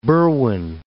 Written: Berwyn

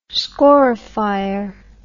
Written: scorifier

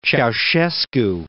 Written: Ceausescu

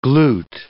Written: glout

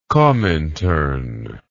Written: komintern

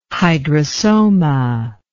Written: hydrosoma

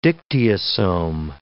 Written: dictyosome

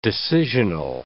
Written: decisional

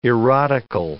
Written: erotical

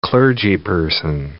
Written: clergyperson

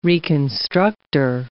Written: reconstructor

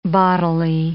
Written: bodily

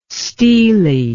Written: steely

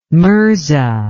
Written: mirza

